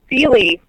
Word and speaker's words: Suehle: It is Suehle, like the mattress company.